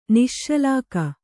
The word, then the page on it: ನಿಶ್ಶಲಾಕ: kannada